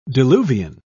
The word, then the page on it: diluvian: British English